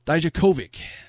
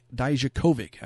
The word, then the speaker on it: Dijakovic: Dijakovic. Dominic Dijakovic. I have to slow it down a little bit when I go for his name. Can't just throw it out there like a Tommaso Ciampa or a Keith Lee, right?